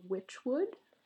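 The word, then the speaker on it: Wychwood: The first one is called Witchwood. – Witchwood? – Uh-huh. It's spelled W-Y-C-H. – Oh. – Wood.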